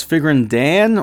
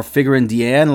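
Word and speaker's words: Figrin D'an: I know you guys have been pointing out multiple times now that I am mispronouncing the name. But, you know, to me, there is an apostrophe between the D and the A, and I've just always called the character Figurine Deanne and not Figurine Dan.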